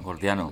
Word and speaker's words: gordiano: Hablabas de la disolución de los nudos antes, sí, y esto me lleva al nudo jordiano. ¿Lo he dicho bien? Jordiano. – Gordiano. – Gordiano, sí, eso.